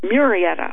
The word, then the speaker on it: Murrieta: Murrieta, California resident, Murrieta, I believe that is pronounced, resident Bob Cowell, and I believe that's spelled capital K-O-W-E-L-L,